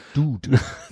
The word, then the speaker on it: Dude: Der wird ohne J, der wird der Dude. Wird, der wird Dude. so ist im Englischen.